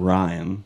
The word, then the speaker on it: Ryne: So there's this guy named Ryan. But it's spelled R-Y-N-E, and he is winning.